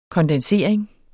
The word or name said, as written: kondensering